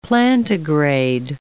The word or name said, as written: plantigrade